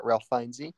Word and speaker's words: Ralph Fiennes: Apparently is how you pronounce it, according to Wikipedia, Ralph Fiennes.